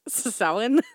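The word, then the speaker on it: Samhain: I was going to say Cam-hain, but it's pronounced Sowin.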